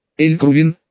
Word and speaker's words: Эль-Крувин: Эль-Крувин, окликнул его Вослав, сделав ударение на приставке, единственное, попрошу вас больше не устраивать боев на территории города.